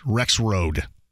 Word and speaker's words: Rexrode: Is it Joe Rexrode or Rexrude? Am I going to pronounce that wrong? – I think it's Rexrode. – I like the way that that pronounced. Rexrode.